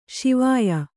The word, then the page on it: ಶಿವಾಯಿ: śivāyi